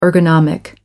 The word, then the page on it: ergonomic: American English